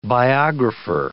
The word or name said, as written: biographer